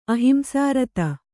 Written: ಅಹಿಂಸಾರತ

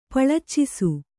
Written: ಪಳಚ್ಚಿಸು

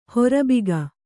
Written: ಹರ್ಬುಗೆ

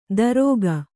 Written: ದರೋಗ

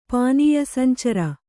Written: ಪಾನೀಯಸಂಚರ

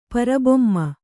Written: ಪರಬೊಮ್ಮ

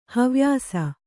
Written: ಹವ್ಯಾಸ

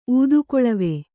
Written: ಊದುಕೊಳವೆ

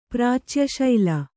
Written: ಪ್ರಾಚ್ಯಶೈಲ

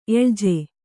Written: ಎಳಜೆ

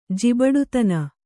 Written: ಜಿಬಡುತನ

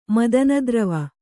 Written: ಮದನದ್ರವ

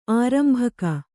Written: ಆರಂಭಕ

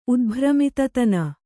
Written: ಉದ್ಭ್ರಮಿತತನ

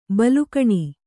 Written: ಬೆಲೆಗಾಣು

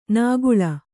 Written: ನಾಗುಳ

